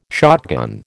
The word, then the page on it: shotgun: American English